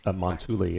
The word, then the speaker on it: Montulli: Did I say that right, by the way? – Montulli, yes. That's very close.